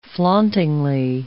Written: flauntingly

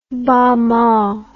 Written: bamaw

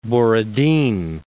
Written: Borodin

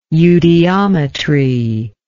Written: eudiometry

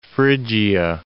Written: Phrygia

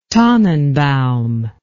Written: tannenbaum

